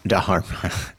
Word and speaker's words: Dharma: Dharma. Thank you. Using your decades in the ashram to correct my pronunciation.